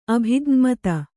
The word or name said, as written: ಅಭಿಜ್ಞಮತ